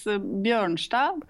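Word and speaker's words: Bjørnstad: It's Bjornstad in Norwegian. – Bjornstad. – So it's like the O is instead of Ø, which is a, you know, special Norwegian letter that you don't have in your alphabet.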